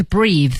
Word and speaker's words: breath: 哦，对，做动词的时候是 breathe。 – 对，所以大家一定注意哈，有 e 的时候 breathe 才是动词，它没有 e 呢，它只能读成 breath，它是一个名词儿啊。